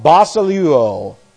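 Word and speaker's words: Basileuo (βασιλεύω): The constitutive aorist active indicative, get this, of basileuo, which looks like this. It's B-A-S, and then we have I-L, and then we have E-U-O. Let's, I'll write it down here. B-A-S-I-L-E-U-O. E-U, long O here. Basileuo.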